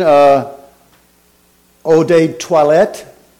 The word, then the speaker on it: eau de toilette: What's the correct pronunciation? Eau de toilette.